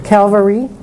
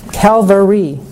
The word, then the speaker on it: Calvary: My dear mother, is it your will that I anticipate the cross, that I go to Calgary?" Am I saying that right? Calgary. – Calvary. – Why am I mixing that up? Calvary.